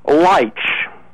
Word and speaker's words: Leitsch: And that is, who was Dick Leitch? I'll spell it, L-E-I-T-S-C-H. It says it's pronounced Leitch. – L-E-I, I'm sorry, would you spell that once more, please? – L-E-I-T-S-H, and it supposedly is pronounced Leitch.